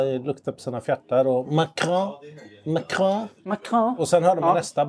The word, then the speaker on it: Macron: Jag har ju alltid sagt Macron. – Mm. – Uttalat det Macron. – Ja. – Och så, jag tror att alla andra har gjort det också. – Mm. – Tills dess att det har hänt en slags skiftning i hans status i svenskarnas ögon, tror jag. Det vill säga, när en fransk president kommer till Sverige... – Mm. – Då skärper folk till sig och börjar uttala namnet på franska.